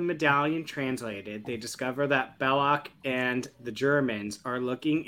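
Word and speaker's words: Belloq: As Indy and Sala are getting the medallion translated, they discover that, I don't know how to say Belloc.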